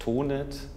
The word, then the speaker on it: Tonet: Also sondern, sondern Tonne, Tonet, Tonet. – Also wir im Rheintal sagen ganz einfach Tonet.